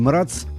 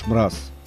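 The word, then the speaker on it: Mraz: Джордж Мраз, по привычке я произношу его фамилию и Мрац, но это чешская фамилия, и, как мы слышим, переводится как «Мороз» Джордж Мраз.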